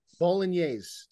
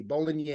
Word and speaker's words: Bolognese: Bolognese. You don't have to pronounce the G. Bolognese. – Oh, okay. Bolognese. – You know, Bob, you know how you say mayonnaise?